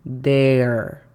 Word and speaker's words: there: La pronunciación fonética se escribiría J-A-I para decir hi, y la pronunciación fonética de there sería D-E-R, con la pronunciación correcta, there.